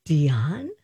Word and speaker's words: Diane: Diane? – Yes. – Really? – That is how you pronounce it. It actually is.